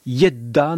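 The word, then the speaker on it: Gedda: Je salue euh, un auditeur qui me signale l'exacte prononciation en suédois de Nikolai Geda. Et oui, il faut dire Yedda normalement en hongrois.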